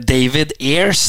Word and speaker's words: David Ayres: David Ayres hørte vi kommentatorer uttale navnet, så da, da, går vi for det.